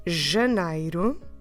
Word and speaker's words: Janeiro: Janeiro.